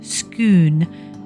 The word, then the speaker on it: Scone: The coronation of King Charles in May helped put the Stone of Scone, pronounced Stone of Scone, on Britain's list.